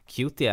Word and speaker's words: Cutie: Cutie är engelska, uttalas Cutie. Alltså K-J-O-T-I. Cutie.